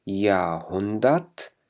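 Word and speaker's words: Jahrhundert: إذن داس يا هندت، وهون كمان الآ لا تلفظ، وهون لا تلفظ إلا بشكل خفيف جدا، يعني تقريبا لا تلفظ.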